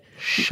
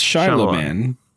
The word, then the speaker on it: Shyamalan: Shyamalan. – That's how you pronounce it. – Shyamalan. It's Shyamalan.